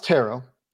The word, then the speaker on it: Turo: Turo, though, sponsors this episode of the Locked On Bengals podcast, pronounced Taro.